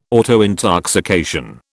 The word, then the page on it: autointoxication: British English